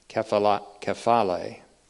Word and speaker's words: kephale: Kephaleion, again, is from the Greek word kephale, which looks like this in the Greek text, K-E-P-H-A-L-E, A to E, kephale, or kephale, if the translation is correct, that has the accent on the last syllable.